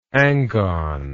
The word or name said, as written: angon